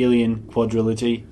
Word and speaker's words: Alien Quadrilogy: We are going to be doing the Alien Quadrilogy.